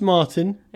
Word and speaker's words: Martin: Just because it's German, you suddenly want to pronounce Martin as Martin? – I don't know. – It's just Martin.